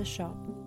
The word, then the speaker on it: scop: I act as a shop. An old English word spelled S-C-O-P, but pronounced kind of like you're a little British and you're talking about a retail shop.